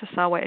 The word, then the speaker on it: Ohsawa: O-H-S-A-W-A. George Asawa.